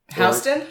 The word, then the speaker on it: Houston: How do you say the Houston street in Manhattan? – Houston? – Yep, exactly. Which is spelled just like Houston, Texas.